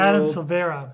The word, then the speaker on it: Silvera: is They Both Die at the End by Adam Silvani. – Let's confirm. – That's not correct. – I probably said it wrong. – It's by... Drummel. – Adam Silvera. – Silvera. Adam Silvera.